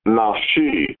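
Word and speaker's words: nafshi (נפשי): Observe that it is written nafsho, his soul, but pronounced nafshi, my soul.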